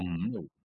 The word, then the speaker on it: Omno: Played and finished a really beautiful puzzle platformer called Omno. Am I saying that right? – Omno.